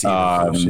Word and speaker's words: Eberflus: Matt Eberflus, is that how you say his name? I'm not quite sure. – Yes, it is. It's Eberflus, yes.